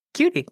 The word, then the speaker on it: Cudi: I know, but C-U-D-I? Cutie. – Why not C-U-T-I-E? Cutie. – I thought it was C-U-D-I.